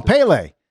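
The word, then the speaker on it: Pelé: Oh, Pele. Pele, right? Pele. P-A-Y-L-A-Y. Pele, the soccer player, Pele.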